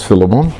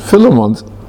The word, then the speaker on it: Philemon: Now, you've got to work out how to pronounce Philemon. The emphasis is in the middle syllable, but is it Philemon or Philemon? And that just depends which side of the railway line you were born on.